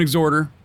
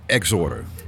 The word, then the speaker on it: Exhorter: The H is silent, motherfucker. but that's how you pronounce it. It's Exhorter.